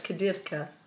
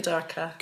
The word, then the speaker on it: Kudirka: Kudirka. If you're in Lithuania, it's Kudirka. – Right. – So either will work. – Kudirka. But you pronounce it Kudirka. – We tend to pronounce it Kudirka.